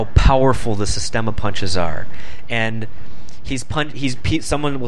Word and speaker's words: Systema: I've seen other videos of the Systema stuff. I think that I'm pronouncing it correctly. It's system with an A.